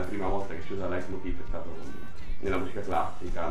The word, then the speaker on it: Leitmotiv: La parola è tedesca, infatti è la pronuncia Leitmotiv, questa parola tedesca.